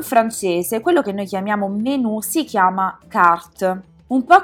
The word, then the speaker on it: menu: In francese non si dice menu con la E aperta e la U, ma menu, quindi con una E di fatto chiusa, quasi muta, e la U che è una via di mezzo tra una I e una U, quindi menu.